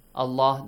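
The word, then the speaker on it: الله: Allah, the word Allah, the Lam, will be pronounced heavily.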